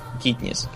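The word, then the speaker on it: Katniss: То есть в голодных играх, начиная с того, что главная героиня, там, Кейтнис, Кейтлин, Кейтнис. Кейтнис. Китнис. – В английском а, произношении, по крайней мере, Китнис.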